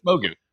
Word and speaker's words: Mogu: Mogu.